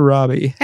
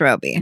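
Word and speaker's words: Robie: Is it Roby or Robbie? – I think it's Roby. – Roby, it's spelled Roby.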